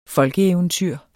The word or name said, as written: folkeeventyr